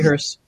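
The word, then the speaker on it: Höss: Now, again, understand what's going on here with Hess. Huss, I guess that's how you say it. It's actually Hearst.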